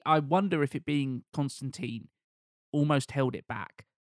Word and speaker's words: Constantine: The comic book character is very notably Constantine. – Mm-hmm. He specifically clarifies his pronunciation. Yeah. – Tine like wine.